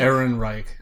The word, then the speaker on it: Ehrenreich: George Lucas apparently walked up to, I can never pronounce his name correctly, Alden Ehrenreich? – Ehrenreich.